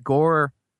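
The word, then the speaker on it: Gorr: Gore just, I just realized now that Gore rhymes with Thor.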